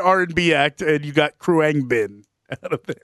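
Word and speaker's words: Khruangbin: I'm going to go with the pronunciation Kruangbin. – Oh, man. – It is spelled Kruangbin.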